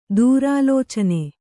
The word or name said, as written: ದುರಾಲೋಚನೆ